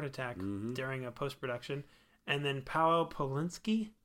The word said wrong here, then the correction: Pawlikowski: for Cold War. – Pawlikowski. – Pawlikowski.